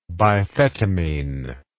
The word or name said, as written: biphetamine